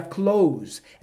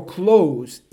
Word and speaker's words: close: Não tem diferença, só a diferença só é na pronúncia, quando você fechar, como fala? Close. Quando você ah, está falando sobre perto, como fala? Close. Pode ver a diferença? Perto é close. Fechar é close.